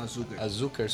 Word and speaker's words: Açúcar: Essa é a pronúncia certa? – Açúcar Sugar.